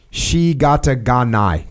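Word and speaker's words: shikata ga nai: There is a phrase in Japanese, shigata ganai. Did I say that right? – That's right. You said it right.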